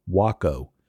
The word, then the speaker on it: Waco: It's spelled like Waco, Texas, but it's pronounced Waco.